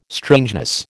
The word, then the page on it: strangeness: American English